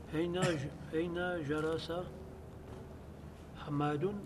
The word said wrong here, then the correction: حامد: حامد. – حامد. – Prononcez le ح. حامد.